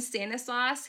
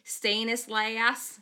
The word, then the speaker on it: Stanislaus: And then Stanislaus, he pronounced like Stanislaus. No, it's Stanislaus.